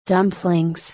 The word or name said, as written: dumplings